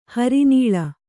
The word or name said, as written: ಹರಿನಾಳಿ